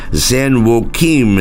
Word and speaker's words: San Joaquin: And my friend Arthur wanted me to know that you pronounce the river in California San Joaquin. San Joaquin is how you pronounce that.